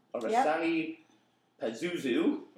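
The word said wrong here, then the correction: Oranssi Pazuzu: Oransi Pazuzu.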